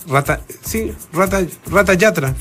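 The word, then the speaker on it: Ratha Yatra: Mira, Rata o Raza Yatra. – Rata Yatra, sí.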